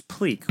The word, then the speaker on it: Pliq: And then there's Pleek, which is spelled P-L-I-Q.